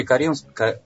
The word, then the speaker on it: Кореновск: Вот на примере Кореновска. Прости, как правильное ударение? Корен? – Москвичи. Кореновск. – Кореновск, извините, да.